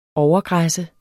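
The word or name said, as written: overgræsse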